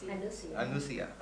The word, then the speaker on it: anusaya: Anusya, yeah, or anusaya, how do you pronounce? – Anusya.